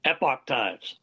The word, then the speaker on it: Epoch: Epoch Times, how do you say it? – Epoch Times.